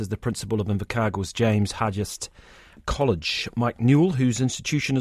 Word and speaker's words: James Hargest: James Hargest, Very good.